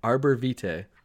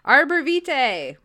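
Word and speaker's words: Arborvitae: Arbor Vitae. – Arbor Vitae, which is the tree of life.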